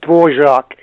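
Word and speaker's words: Dvořák: I don't know how the Americans would pronounce that. We say Dvorak in Czechoslovakian.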